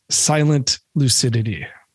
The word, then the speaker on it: Silent Lucidity: So track 8, Silent Lucidity. – Oh, you said it right too. Nice job.